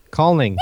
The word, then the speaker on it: Kulning: It was, I don't know how to say this word, a Norwegian film, Illing. – What is it called? – Illing? – Did you say Kulning? – Kulning. There it is.